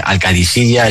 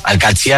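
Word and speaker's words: Al-Qadisiyah: Bueno, se suele decir Al-Katsi, pero también hay gente que lo dice Al-Kadisiyya. Es que es difícil, ¿eh? Ah, no es fácil, pero Al-Katsi es lo más fácil.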